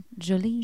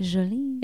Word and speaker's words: Jolie: Jolie. – Jolie? – Jolie.